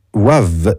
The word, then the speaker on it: WAVV: Ils ne seront pas seuls, il y aura aussi en première partie Wavre, Wavre, W-A-V-V. Waves, on va le prononcer Waves, mais avec deux V, donc c'est pas simple cette prononciation, j'ignore si c'est vraiment de l'anglais avec une faute d'orthographe ou pas, je ne sais pas.